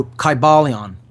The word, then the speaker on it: Kybalion: It's spelled K-Y-B-A-L-I-O-N, Kybalion.